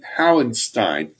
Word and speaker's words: Hauenstein: Again, I'm Dan Hauenstein, so you got the pronunciation exactly right.